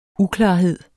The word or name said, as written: uklarhed